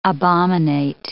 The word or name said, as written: abominate